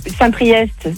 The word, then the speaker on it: Saint-Priest: Saint-Priest, c'est EST, tu le prononces.